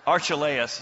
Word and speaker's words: Archelaus: was Herod Eclechius, or however you say that. But whenever I pronounce something, it's wrong, just so you know. Archelaus is probably more accurate, was reigning over Judea.